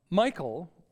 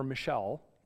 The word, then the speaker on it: Michal: As the ark of the Lord was entering the city of David, Michal, or Michal, depending on how you want to pronounce it, Michal, daughter of Saul, who was David's wife, watched from a window.